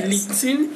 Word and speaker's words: LinkedIn: LinkedIn, ici on dit LinkedIn. LinkedIn, excusez-moi, j'ai mal, prononcé. LinkedIn LinkedIn.